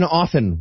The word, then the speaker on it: often: Also, anyone who pronounces the T in often while we're on the subject.